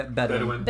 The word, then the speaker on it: Bedouin: Is it the Bedouin? – Yeah, Bedouin. – Bedouin. He revealed to him his true nature and who became the only person who could be labeled as his friend.